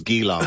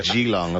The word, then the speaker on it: Geelong: I thought it was Geelong. But it's Geelong, apparently.